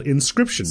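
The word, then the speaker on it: Inscryption: Called Inscription. – So good. – In which Cryption is spelled like the word crypt.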